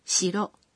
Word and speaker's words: 城: 城堡的城字没有接在固有名词后面，而是单独使用的时候读作 shiro。